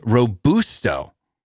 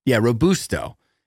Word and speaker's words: Robusto: However, yeah, I missed the whole Robusta thing. Yeah. – Robusta you went bad, buddy. – However, Kathy Courtney, 9164, said, I'm sorry, but it's pronounced Robusto. Robusto.